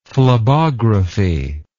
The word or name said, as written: phlebography